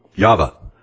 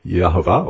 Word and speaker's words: Yahweh: And of course I'm transposing in the King James the L-O-R-D back to its proper rendering from the Hebrew of Yahweh, or Y-H-V-H, or Yahovah. It can be pronounced Yahovah or Yahweh. And there may be other pronunciations as well.